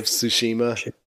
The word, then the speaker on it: Tsushima: Ghosts of Tsushima. – Tsushima.